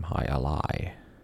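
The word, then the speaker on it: jai alai: 34 across, word after, I believe it's pronounced high, J-A-I, in a sports name, high ally, A-L-A-I. Hmm. 4 across, woman, in French, I believe is fille, F-I-L-L-E.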